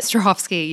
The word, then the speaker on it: Strahovski: I mean, it's not technically the original traditional Polish way of saying it, but that's the English version that I've come up with. – So what would you say traditionally? – It's Strahovski, which involves a whole lot of consonants without breaking them up with vowel sounds, which no one can do if you don't speak some kind of European language, I think. It's like sneezing. You have to kind of go Strahovski.